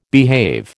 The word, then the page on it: behave: American English